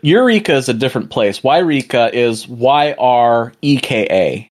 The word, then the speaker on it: Yreka: Is it Yreka or Eureka? Because I know some people are like, it's Eureka. – No, it's Yreka. Like, it's spelled Y-R-E-K-A.